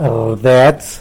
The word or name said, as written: rdets